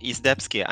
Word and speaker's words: Izdebski: Izdebski. I mean, Polish last names are quite difficult, so it's a point for you.